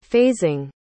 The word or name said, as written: phasing